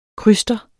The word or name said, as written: kryster